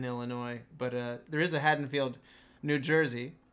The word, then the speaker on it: Haddonfield: So, last week, as we I said let's go to Haddonfield, and apparently, as you guys have both correctly pronounced it this way, it is Haddonfield. I've been saying it wrong for 15 years now.